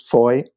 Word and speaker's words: Fowey: And on the south, there's a town called, I'm going to call it Foy. It's spelled F-O-W-E-Y. And a bit like you, Kevin, some people told me, no, it's Foy. But I think it's Foy, and I think locals call it Foy.